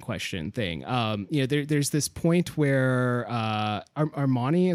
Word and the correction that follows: Ameni: is that how you pronounce her name? – Ameni.